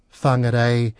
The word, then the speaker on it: Whangarei: But just so that things aren't so complicated, if you see a WH sound, it's pronounced as F. So Whakatane, Whangarei, and if you want to, Whanganui.